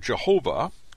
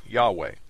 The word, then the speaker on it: Yahweh: In English, these four Hebrew letters, YHWH, called the Tetragrammaton, were formerly rendered Jehovah, King James Version, but are better pronounced Yahweh.